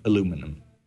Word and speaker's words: aluminum: from aluminum. There, I said it right.